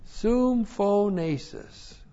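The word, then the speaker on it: Sum phonesis (συμφώνησις): And then we have sum phonesis. Looks like that in the Greek, S-U-M-P-H, long O, N, long E, S-I-S. Sum phonesis.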